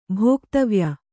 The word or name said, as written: ಭೋಕ್ತವ್ಯ